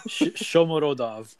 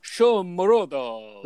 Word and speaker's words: Shomurodov: How do you say his name? – Shomurodov. – Shomurodov. – There we go.